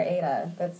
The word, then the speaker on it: Eide: Which mine is Sarah Ada. I think that flows pretty well. But my last name is spelled E-I-D-E.